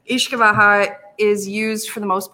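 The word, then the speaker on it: uisce beatha: It's kind of written similar to like Uski Betha, but Ishkabaha is the pronunciation.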